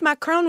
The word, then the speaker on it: Macron: Am I saying that right? Macron. – Nice.